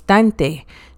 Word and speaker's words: danite: Technically you spell it like danite, but the people don't pronounce it like that, so it's dante.